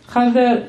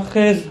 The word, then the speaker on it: خضر: آن پسری را که خضر یا خز، هر دو طریق تلفظ شده، آن پسری را که خز حلقش را ببرید و کشت، سر آن را مردم عامه و مردم عادی درک نمی کنند.